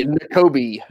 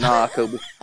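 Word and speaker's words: Nakobe: I say N'Kobe. – N'Kobe. – You take Kobe Bryant and put nah in front of it. Nah, Kobe.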